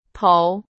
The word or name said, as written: 头